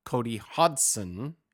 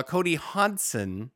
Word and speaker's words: Hodgson: One for sure named Cody Hodgson. You don't pronounce the G, my friend.